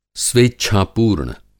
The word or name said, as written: स्वेच्छा-पूर्ण